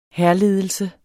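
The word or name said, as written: hærledelse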